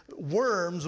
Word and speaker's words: Worms: The diet means a meeting, and worms, we pronounce it worms, but it was actually Worms in German.